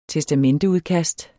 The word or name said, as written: testamenteudkast